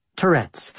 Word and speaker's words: Tourette's: Tourette's.